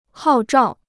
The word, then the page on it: 号召: hào zhào